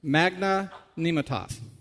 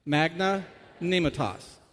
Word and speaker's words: magnanimitas: In fact, this word is pronounced, I think, magna nematos. Why don't you try saying it? Magna nematos.